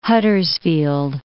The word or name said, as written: Huddersfield